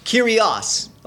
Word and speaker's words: Kyrios: The word in the Greek is, and I think I'm pronouncing it correctly, Kyrios. Yeah, close enough? – Kyrios. – Okay, thank you.